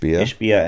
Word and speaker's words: Ishbia: Ishbia and Steve St. Andre, who are fully funding the entire $95 million contract.